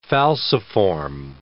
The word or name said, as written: falciform